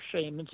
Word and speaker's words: shamans: But the thing that most people don't realize is that shamans from around, or shamans, either pronunciation is correct, shamans from around the world often engage in what we would call lucid dreaming.